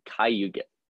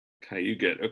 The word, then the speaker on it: Cayuga: So you say Cayuga, right? That's because I was looking at it when I was on the menu and I'm like, I can't even pronounce this. – Yeah, I know. It's a hard like, yeah, it's harder to pronounce. And then when you look at it, you're like, huh? And Yeah. – then, but yeah, so it's Cayuga. – Cayuga.